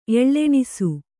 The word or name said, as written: ಎಳ್ಳೆಣಿಸು